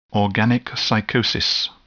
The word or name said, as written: organic psychosis